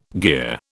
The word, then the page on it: gear: British English